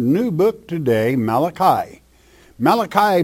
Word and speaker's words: Malachi: And many people pronounce it Malachiah, which is to say messenger of God. But inasmuch as it's God speaking, we'll leave it my messenger.